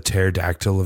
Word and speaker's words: pterodactyl: I feel like there's some joke to be made that pneumonia is the pterodactyl of diseases, but it's not really that funny. And I don't know if it's technically a disease. They just both have a silent P up front.